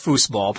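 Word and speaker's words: Fußball: A company that made these games before actually called it foosball, F-O-O-S. But according to my information, the word in German for foot is F-U-S-S, foosball. Pronounced the same, but spelled slightly differently.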